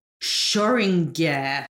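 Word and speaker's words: Xoriguer: So let's have a look at how we pronounce this gin. Schoringer. – Schoringer. – Schoringer. Schoringer. – Right, good.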